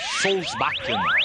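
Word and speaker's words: Solbakken: Solsbakken.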